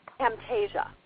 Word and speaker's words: Camtasia: Camtasia. – Can you spell that? – C-A-M. – Oh, C-A-M, Camtasia, okay.